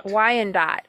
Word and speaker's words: Wyandot: But I think it was, is it the Wyandot or how do you say that? – Wyandot.